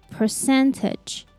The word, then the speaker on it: percentage: And we also stress the second syllable, so it sounds like percentage, percentage.